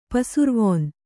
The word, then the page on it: ಪಸುರ್ವೊನ್: kannada